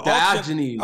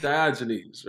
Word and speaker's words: Diogenes: Diogenes on the Diogenes.